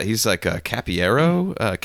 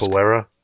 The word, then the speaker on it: Capoeira: Yeah, he's like a Capiero, Capiera. How do you say that? I gotta ask. – Capoeira.